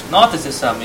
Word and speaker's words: Gnosticism: Gnosticism. You don't pronounce the G. Gnosticism. Gnostics. This word means knowledge. So Gnostics mean knowledge. Gnosticism is the noun of the thinking.